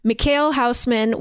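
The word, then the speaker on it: Mikhail Huisman: But it is starring Mikhail Houseman, which I looked up how to pronounce it, and that is the correct pronunciation. – Nice. – It's spelled like Michael Huseman.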